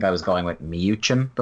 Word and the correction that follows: Mewson: before now, but I've heard a lot of people say Mewson since. So that is what I'm going with now.